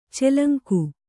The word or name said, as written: ಚೆಲಂಕು